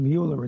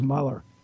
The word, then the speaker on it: Mueller: And by the way, one of my little annoyances, if you go to pronunciation check and you hear it pronounced, I don't know why everybody calls him Mueller, it's Mueller.